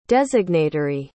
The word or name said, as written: designatory